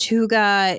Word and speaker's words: hygge: For the, it's like this thing, H-Y-G-G-E, pronounced Huga.